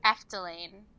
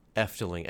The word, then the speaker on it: Efteling: How's it pronounced again? – Efteling. – Efteling.